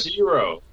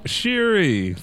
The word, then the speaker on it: Shuri: Shiro. Is it Shiro? – Shiri for Black Panther. This is Shiri. – Oh, that's how you pronounce it. – Yeah. – Honestly, I couldn't remember how to pronounce her name. – Oh, man. – Why is there an O there? – I don't know, because I put Shuri. It's supposed to be Shuri, so I don't think that's autocorrect or something.